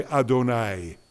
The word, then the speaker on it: Adonai: And now the third name for God in Hebrew, the one we pronounce, the euphemism, instead of saying, we say Adonai.